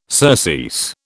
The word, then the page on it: surcease: British English